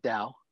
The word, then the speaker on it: Tao: Do you pronounce it Tao or Dao? Um, I pronounce it Dao.